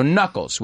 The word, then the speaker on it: knuckles: So knuckles with a K, K-N. – Mm-hmm. – Knuckle, se escribe, right? Knuckles. – Yeah, you don't pronounce the K. – So that's what she says. – You don't pronounce the first K.